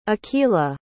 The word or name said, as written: aqila